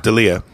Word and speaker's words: D'Elia: But the night before, I did the Laugh Factory, and that was a powerhouse lineup of Chris D'Elia. Am I saying that right? – D'Elia.